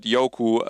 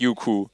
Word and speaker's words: Youku: Und es wird Youku ausgesprochen, nicht Youku.